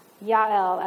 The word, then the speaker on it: Yael: And when no military were around, Yael, as I like to say the, the Hebrew pronunciation because it sounds fancier, she stepped up and it makes me think again, Rosie the Riveter, where are the men?